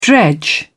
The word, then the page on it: dredge: British English